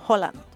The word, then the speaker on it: Haaland: Como Haaland, no es Haaland, es Holland.